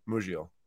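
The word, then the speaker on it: Musial: How do you pronounce it again? Muziel or is it Muziel? – Stan Muziel. – Muziel. All right, cool.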